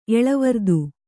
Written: ಎಳವರ್ದು

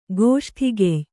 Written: ಗೋಷ್ಠಿಗೆಯ್

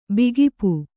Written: ಬೇಕೂಪ

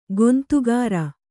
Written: ಕುಂದುಕೇರು

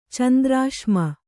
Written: ಚಂದ್ರಾಶ್ಮ

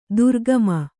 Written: ದ್ರಾಘಿಮ

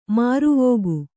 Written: ಮುರವಿಕ್ಕು

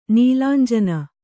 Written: ನೀಲಾಂಜನ

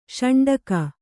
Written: ಶೌಂಡಿಕ

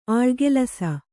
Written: ಆಳುಗೆಲಸ

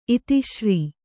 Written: ಇತಿಶ್ರೀ

